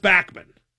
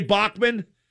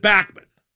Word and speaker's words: Bachman: And it is pronounced Bachman. Most people say Bachman. He pronounces it Bachman. So it's Bachman.